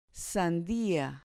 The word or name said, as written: sandia